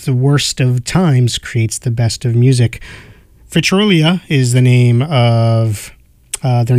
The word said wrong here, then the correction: Vitriola: And I think I pronounced the name wrong. Vitriola is the name of the new album.